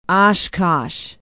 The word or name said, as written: oshkosh